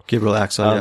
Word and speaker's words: Gabriel Axel: It's from 1987, directed by, is it Gabriel Axel? I think is how you say it. – Gabriel Axel. Yes.